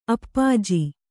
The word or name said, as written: ಅಪ್ಪಾಜಿ